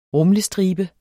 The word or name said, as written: rumlestribe